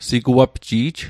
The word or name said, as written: siguapji'j